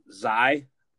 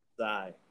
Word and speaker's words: Xia: How do you say her name? It's XIA. – Zai. – Zai. Z and Zai.